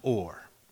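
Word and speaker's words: or (עור): The word for skin in Hebrew is pronounced or. Okay? Which is the exact same pronunciation for the word for light, or.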